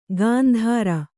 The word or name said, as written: ಗಾಂಧಾರ